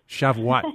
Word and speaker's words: Shavuot: I call it Shavuot. How do you pronounce it? – Shavuot.